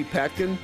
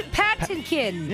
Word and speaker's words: Patinkin: M-A-N-D-Y. – Mandy. – It's Patinkin. – Yeah. – Not Mandy Patkin.